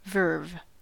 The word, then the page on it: verve: American English